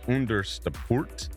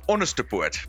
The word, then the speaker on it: Onderstepoort: How do you say Onderstepoort? – You did a very good job, you know. I think you've been practicing. It comes across Onderstepoort, but you really got it.